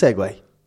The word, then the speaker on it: segue: Is S-E-G-U-E. – Yeah. Yeah, the verb, like. – But that doesn't even pronounce segue. – Huh? – S-E-G-U-E. – Ah, it does, yeah. – Segue. Segue.